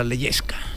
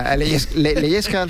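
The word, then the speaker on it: Lehečka: Ti y a Leyesca. – Leyesca, mire, lo estaba yo pronunciando mal.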